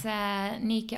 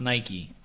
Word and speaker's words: Nike: And it's spelled N-I-K-E, but it's not pronounced Nike.